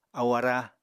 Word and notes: ahu ará: Apatani

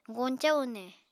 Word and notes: goñcañ une: Apatani

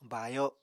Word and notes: baayo: Apatani

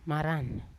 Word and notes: maran: Yawa

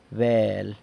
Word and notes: bel: San Lucas Quiaviní

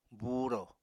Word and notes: buuro: Apatani

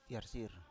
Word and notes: fiarsir: Biak

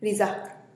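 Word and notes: rizak: Teotitlán del Valle Zapotec

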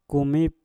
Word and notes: kumik: Mpur

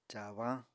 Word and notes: caabañ: apatani

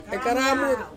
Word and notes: karamut: Ansus

